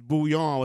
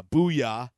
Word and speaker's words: bouillon: We called him out on his pronunciation of bouillon. – He blamed it on his lack of a French accent. – Yeah, his bouillon was more of a booyah, which we found very comical.